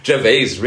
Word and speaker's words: Gervais: Ich, wir haben ihn jetzt beide falsch ausgesprochen. Gervais, Ricky Gervais.